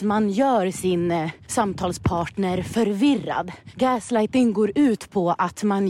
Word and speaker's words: gaslighting: Jag blandar ihop det med grease lightning. Det är gaslighting, så säger man.